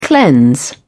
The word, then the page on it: cleanse: British English